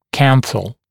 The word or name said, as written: canthal